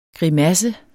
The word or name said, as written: grimasse